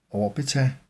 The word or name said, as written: orbita